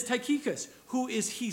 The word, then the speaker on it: Tychicus: But I call him Tychicus.